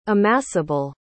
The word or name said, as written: amassable